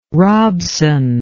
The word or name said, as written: Robson